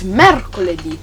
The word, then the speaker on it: mercoledì: That is mercoledì. This one's a little difficult. Want to try? – Mercoledì.